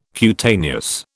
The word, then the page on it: cutaneous: British English